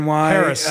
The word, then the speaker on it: Peres: Dan Perez NY on Twitter. – So, right. So, at Dan Perez NY. – Paris. I said it wrong.